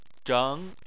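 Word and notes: zheng: tone 2

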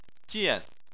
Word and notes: jian: 4

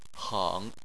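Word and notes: heng: tone 3

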